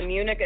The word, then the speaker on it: Munich: Munich.